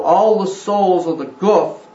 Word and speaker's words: Guf: It is also the place that we call the Guf, G-U-F or G-O-P-H, however you want to spell it to announce it as, pronounce it as Guf.